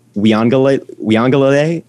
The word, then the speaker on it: Uiagalelei: Also, my Heisman pick is DJ Uiagalelei. That's how you pronounce his name.